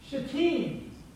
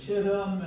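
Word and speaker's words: Shittim: Some people don't like to say it the way phonetically we typically would say that, and so they say chittim, and they do all kinds of weird things with it, but it is shatim. And the pronunciation and the emphasis, the accent, I guess is the right way to say it from an English language, the accent is on the second syllable, shatim.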